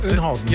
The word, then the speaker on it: Oeynhausen: Kreuz Bad Oeynhausen. Auch so? – Oeynhausen.